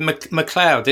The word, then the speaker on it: MacLeod: MacLeod.